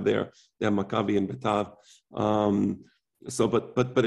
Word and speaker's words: Betar: He writes here, Beta, the, um, Later on, my father told me the pr- proper pronunciation is Betar, um, and so I follow the proper.